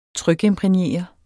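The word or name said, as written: TRYKIMPRÆGNERET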